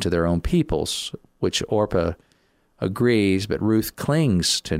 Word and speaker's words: Orpah: She was actually named Orpah, but it was mispronounced Oprah so many times she just went with it.